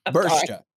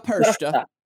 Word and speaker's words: Perchta: Bershta. Pershta. – Or it's a hard C. I don't know. – It's Frel Pershta. – Yeah. – P-E-R-C-H-T-A.